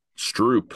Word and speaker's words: Stroop: The way you pronounce it is already Strop, but in Dutch, you actually write it with two O's. – Stroop. – Stroop. – Like a Stroop waffle.